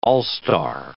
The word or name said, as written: all-star